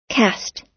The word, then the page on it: cast: American English